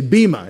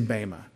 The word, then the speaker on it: bema: If you hear people say bema, they're pronouncing it with a modern Greek pronunciation. But I was taught an Erasmian pronunciation, and the Ada says A. So I say bema.